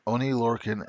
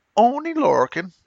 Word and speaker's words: Oney Lorcan: This was Kyle O'Reilly and Roderick Strong taking on Oney Lorcan and Danny Burch. Oh, I'm sorry, I said the name wrong. Oney Lorcan.